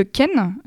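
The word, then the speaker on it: khen: Il y a aussi le ken, qui est une flûte.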